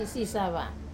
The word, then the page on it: sava: Wamesa